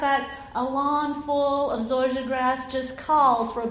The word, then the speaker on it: zoysia: My dad, unfortunately, had a love of zoysia grass, spelled Z-O-Y-S-I-A, but pronounced zoysia.